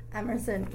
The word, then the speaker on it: Emerson: Jillian, J-I-L-L-I-A-N, Emerson, E-M-E-R-S-O-N.